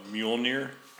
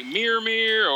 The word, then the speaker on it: Mjolnir: What, Mjolnir? – Is that how you pronounce it? – Sure. – There's the train. – According to Marvel.